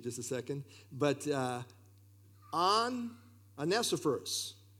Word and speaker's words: Onesiphorus: Just remember the first two letters, on. Because always, how do you pronounce this? Is it one F servers? How do you pronounce this? It's on. Onesiphorus. Anyway, you want to try it, Jeff? – Yeah.